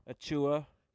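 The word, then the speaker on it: Achiuwa: How do you say Achua? – Achua.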